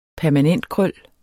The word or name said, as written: permanentkrøl